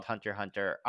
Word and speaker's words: Hunter x Hunter: I mean, the degree of, you mentioned, so, oh, are you reading or watching Hunter x Hunter for? – A little bit. Is that how it's pronounced? – It is. – Is the X silent? – I know.